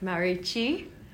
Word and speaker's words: Marucci: Look, I hate to correct you, but it's kind of more like Marucci.